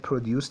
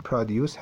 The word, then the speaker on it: produce: نباید produce تلفظ بشه، این produce هست و uncountable هم هست، اسم هم هست.